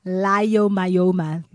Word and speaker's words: Leiomyoma: Leiomyoma.